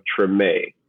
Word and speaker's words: Treme: Born and raised in the city of New Orleans, downtown to be specific, and even more specific, in the neighborhood of Treme. That's pronounced T-R-E-M-E, Treme. And so the show that you saw on HBO, that show was named after my neighborhood that I grew up in.